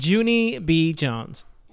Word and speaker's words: Junie B. Jones: What did I say it wrong? – Yes. Jenny B. Jones. – Oh, she would probably scream at me if I yeah. said that wrong. Junie B. Jones.